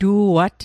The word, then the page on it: tu'at: Mi'gmaq